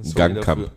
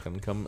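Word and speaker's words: Ngankam: naja, Jessica Nankam, äh, ich kann den Namen nicht richtig aussprechen. – Gankam. – Gankam.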